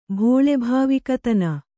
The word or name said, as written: ಭೋಳೆಭಾವಿಕತನ